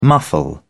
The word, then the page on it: muffle: British English